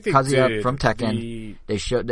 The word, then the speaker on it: Kazuya: So we had a direct where they showed – is it – how you pronounce it? Is it Kazuya or Kazuya? Kazuya, okay.